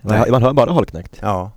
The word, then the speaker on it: Holknekt: Uh, hur uttalas det då? Långt O. Hol, uh. Holknekt. Holknekt.